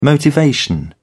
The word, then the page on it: motivation: British English